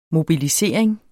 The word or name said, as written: mobilisering